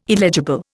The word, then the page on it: illegible: Indian